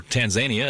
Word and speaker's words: Tanzania: Tanzania, or Tanzania, as they call it. – Do they call it that? – Yeah. – I guess we're mispronouncing it.